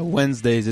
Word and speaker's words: Wenzdaze: Are we going with Wednesdays? Like with a Z? Like how is that pronounced? – Yeah, Wednesdays. It's spelled Wenzdaze.